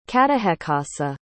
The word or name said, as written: catahecassa